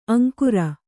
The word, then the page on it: ಅಂಕುರ: aŋkura